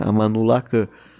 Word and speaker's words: Amanullah Khan: É, porque aqui são, são sílabas mais próximas do português, né? Amanullah Khan. Se você tem um H com som de R, daí já, já arrebenta a minha, minha cabeça. Mas Amanullah Khan tá tranquilo de falar, por enquanto.